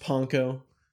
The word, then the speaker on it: panko: And is it pronounced panko? – Yeah.